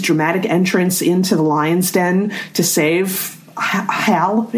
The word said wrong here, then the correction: Haru: It is Hal, right? – I think it's pronounced Haru, but yeah, I got it.